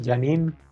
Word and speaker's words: Janine: Y que, lo, y que eh, Janie, Jenny, se, llama el personaje, si no me equivoco, que mm. interpreta Annie Post. ¿Se pronuncia así? ¿Jenny? Mm. Janine. Janine, bueno.